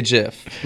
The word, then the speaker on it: GIF: I say GIF. Mom's like, you choose GIF. – Yeah, well, newsflash, the person who invented it said it's pronounced GIF.